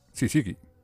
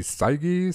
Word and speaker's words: Syzygy: Para nada, de hecho se escribe S-Y-Z-Y-G-Y. Sí sigue. – es lo más fácil de pronunciar para la mayor parte del mundo civilizado, menos para los de habla inglesa. – En inglés dirían sai Saisigi, Saisigi.